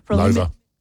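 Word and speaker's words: Loewe: You can upgrade your home entertainment with the premium Lowy TVs at Harvey Norman. – Lova. – Oh, is it Lova? – See, at the top, how it says Lova? – Oh, yes. – Almost rhymes with sofa? – Yep.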